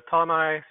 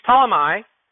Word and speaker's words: Ptolemy: When they completed our voyage from Tyre, we reached, I don't even know how to say it, Ptolemy, I think it is. I know the P is not pronounced, so I'm not sure, but it doesn't matter.